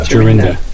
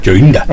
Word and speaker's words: Jorinda: J-O-R-I-H? – I-D-A. – J-O-R-I-N-D-A. – Jarinda. – Jarinda. The N was silent. – The N is silent. – Yes, north. – Jarinda.